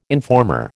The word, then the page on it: informer: American English